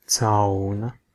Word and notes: marter: /ˈmɑr.tər/

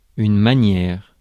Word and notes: bearing: American English; IPA: [ˈbɛə.rɪŋ]

